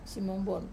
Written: simonbuon

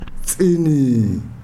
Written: tsini

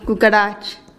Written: kukarach

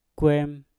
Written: kwem

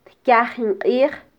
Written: tkyajin qij

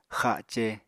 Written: xace

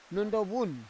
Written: nondovun

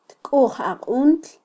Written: tkujaquntl

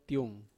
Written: tuing

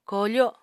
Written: koofo

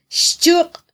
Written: xchuq

